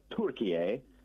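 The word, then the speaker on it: Türkiye: or I was asked to call it not Turkey, but Turkiye, which is mm-hmm. the correct pronunciation.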